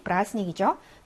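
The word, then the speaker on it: 브라스닉 (праздник): 스 플러스 족격을 빼고 나머지 가운데 남아있는 아이를 보니 브라스닉이죠. 대발음이 묵음입니다. 브라스닉.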